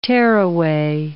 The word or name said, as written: tearaway